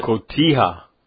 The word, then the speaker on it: Cotija: Has corn, garlic, lime juice, salt, black beans, red bell pepper, red onion, chopped cilantro, and I can't pronounce this, cojita cheese that crumbles, that Mexican cheese. You can get it at the grocery store. Cotija. I get you could probably pronounce it better than I. But Cotija. – Cotija.